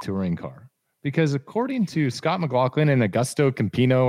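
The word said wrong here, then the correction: Agustín Canapino: Augustine Canapino, man.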